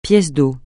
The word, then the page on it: pièce d'eau: francais